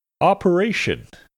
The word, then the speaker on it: operation: Operation. Operations accent should be put on the third syllable, re.